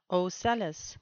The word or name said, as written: ocellus